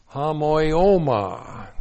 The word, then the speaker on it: homoioma: The preposition en is in, correctly translated in, and then we have a very important word here, homoioma. Looks like that in the Greek, H-O-M-O-I, long O-M-A. Homoioma.